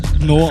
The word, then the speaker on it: DNO: Eigentlich No ausgesprochen. Das kommt aus dem Russischen und heißt so viel wie Untergrund oder White Trash, habe ich mir sagen lassen.